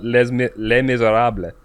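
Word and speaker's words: Les Misérables: Before people get, like, really up in arms, I know it's pronounced Les Miserables.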